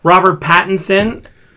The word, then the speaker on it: Robert Pattinson: Robert Pattinson testing. Did I say it right? – Yes, you said it right.